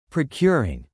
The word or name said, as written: procuring